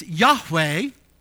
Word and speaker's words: Yahweh: It's Yahweh, if you want to pronounce it.